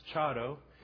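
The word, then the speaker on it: Charo: And her name is pronounced Chado. And even though it's C-H-A-R-O, come on up, dear. It's better to do the R with like a dead D. Chado.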